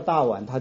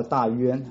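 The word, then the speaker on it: 大宛: 我不晓得，就是那个时候记载的一些地名很奇怪啊，他不照正常的发音哈，可能是当时的发音全部都是破音字，他不叫大碗，他叫大渊啊，他不叫那个大月氏，他叫大肉汁哈，然后不叫那个深读叫捐读哈，印度叫捐读。 anyway 这个是那个大渊哈，大渊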